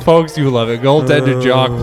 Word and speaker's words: Jacques: It's Jacques.